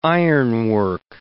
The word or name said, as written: ironwork